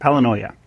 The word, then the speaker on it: palinoia: So today's was Palinoia. That's how you say it. Palinoia.